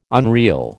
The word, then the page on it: unreel: American English